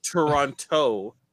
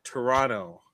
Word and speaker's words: Toronto: It's not Toronto, like T-O-E. It's Toronto. – Or you some people say, some people call it Toronto. – Toronto. They still don't pronounce the second T.